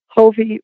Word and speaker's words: Hofve: Hovey rhymes with Bon Jovi.